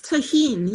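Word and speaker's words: Tajín: it is, she takes one beer, Heineken alcohol free, any fruit juice, limeade or lemonade, ice and Tajin seasoning, I think it's pronounced tahine.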